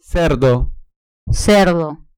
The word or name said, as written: cerdo